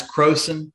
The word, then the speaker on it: Croson: It's Croson. Crow like the Croson.